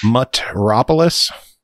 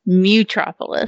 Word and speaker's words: Mutropolis: Metropolis? – No, it's Mutropolis. – Mutropolis. Really? – Yeah, surprise, bitch. – That is shocking. I thought it was Metropolis.